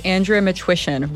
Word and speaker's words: Matwyshyn: Yes, Andrea Matwyshyn, rhymes with permission.